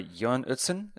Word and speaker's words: Jørn Utzon: designed by Jørn Utzon. Is that right? – Brilliant pronunciation.